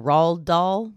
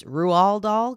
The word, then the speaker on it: Roald Dahl: I've been saying Roald Dahl my whole life. – Oh. – Found out today it's Roald Dahl because he's Norwegian.